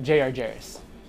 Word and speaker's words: J.R. Jarris: But he goes by the name of J.R. Jarris.